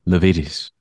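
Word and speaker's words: Lavietes: Levitis, yes. – Not Levitis, like one idiot on this podcast was previously pronouncing it.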